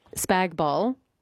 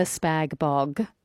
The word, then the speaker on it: spag bol: Spag bol, never spag bog, by the way. You wouldn't say that to me, would you? Because you'd be sacked if you do, because who wants to eat bog? – Yeah, no thanks.